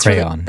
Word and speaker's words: crayon: Not only like two syllables, but also... like a hard cray. – crayon. Cray as in the cray button.